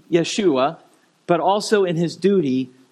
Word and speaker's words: Yeshua: Now, how do you say Jesus' name in Hebrew? Yeshua.